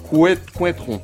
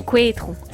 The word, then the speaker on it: Couëtron: Comment ça s'appelle? – C-O-U-E-T-R-O-N. – Couëtron. – Couëtron.